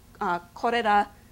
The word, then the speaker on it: korera: Korera would be the Japanese pronunciation of cholera if you Romanized it, and korori.